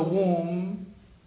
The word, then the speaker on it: womb: such that whoever joins with the womb, Allah will join them, meaning that keep them connected. And whoever separates, then Allah will separate them. Womb has a silent letter in it. It's B.